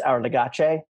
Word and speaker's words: Our Legacy: It's pronounced Our Legache. Just a little heads up.